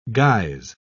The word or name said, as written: guise